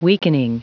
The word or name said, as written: weakening